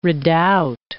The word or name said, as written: redoubt